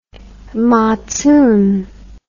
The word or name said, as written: matzoon